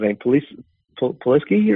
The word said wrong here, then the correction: Pulisic: or what's his name? How you pronounce it? – Pulisic.